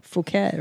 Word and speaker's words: Phuket: But everybody calls it Fuck It Thai. – Of course. – It's so funny. – Yeah. Well, you know, that's not how you say it, though. – Phuket, right? – Yeah, yeah. – Phuket. Yeah. Phuket Thai.